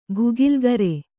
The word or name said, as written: ಭುಗಿಲ್ಗರೆ